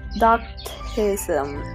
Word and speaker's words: Docetism: Of course, this is what the text means, but John purposely used the word flesh to combat a heresy called doctism. It's spelled D-O-C-E-T-I-S-M.